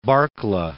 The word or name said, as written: Barkla